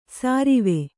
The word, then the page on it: ಸಾರಿವೆ: sārive